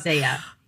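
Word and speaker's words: ZE:A: Because he's from the idol group Zia, and I don't think I'm pronouncing that right. – Zaya. So we actually got into this because MC Won is from Zaya as well. – Okay. – So we had one night where we actually had to look it up. Looked it up. Zaya.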